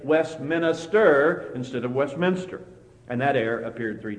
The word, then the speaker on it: Westminster: He had heard it wrong, and he always said Westminster instead of Westminster. He made a four-syllable word out of a three-syllable word.